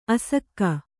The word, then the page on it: ಅಸಗ: kannada